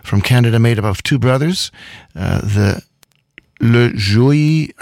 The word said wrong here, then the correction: Lajoie: I think is the way you pronounce their last name. Do you have information? – I can tell you information. They are Jean-Luc La Jouy. – Jean-Luc Lajoie.